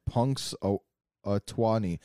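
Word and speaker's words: Punxsutawney: Here's the pronunciation. Punks Twani. I was close.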